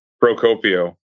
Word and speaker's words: Procopio: It's Procopio. – Procopio. – It's literally exactly how it's spelled.